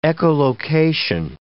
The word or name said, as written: echolocation